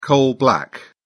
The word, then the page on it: coal black: British English